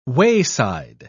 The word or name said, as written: wayside